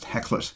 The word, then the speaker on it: Hakluyt: It's written in the first person from the point of view of Boyd Hacklett, and that's spelt H-A-K-L-U-Y-T, which is a Norwegian name, but I believe it's pronounced Hacklett if it's an American or British surname.